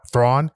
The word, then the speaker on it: Thrawn: I think it's Admiral Tan. or Thrawn, Admiral Thrawn. That's what it is.